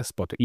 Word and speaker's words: Spotix: Erstmal die erste Frage, wie spricht man euch überhaupt aus? Spotix oder Spotix? – Ja, das Hanseatische, also Spotix.